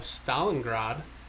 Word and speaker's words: Stalingrad: The Battle of Stalingrad. Say that right? – Yep.